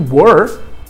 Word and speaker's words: were: Importante saber que were parece muito com where, que é onde. Só que o where se escreve com H e a pronúncia fica aberta. Where. Né? A palavra onde ou aonde. E were não tem H e você não escreve com você pronuncia ele mais fechado, were.